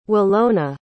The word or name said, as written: Wilona